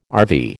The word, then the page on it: RV: American English